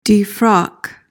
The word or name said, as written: defrock